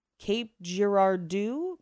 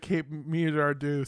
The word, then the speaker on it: Girardeau: And I'm definitely going to pronounce this wrong. I'm so sorry. Girardeau. Cape Girardeau, Missouri.